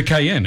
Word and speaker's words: Cayenne: What about how do you say Cayenne? The type of Porsche it was. – It's a Cayenne. – It's a Cayenne pepper? – As in, exactly. Yeah, that is exactly what it's named after because, you know, it's a hot performance four-wheel drive.